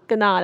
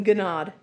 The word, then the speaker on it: Gounod: G-O-U-N-O-D. Gonad. I don't want to say Gonad. G-O-U-N-O-D. Gonad? Gonad, I think, is how it's pronounced. – Gonad.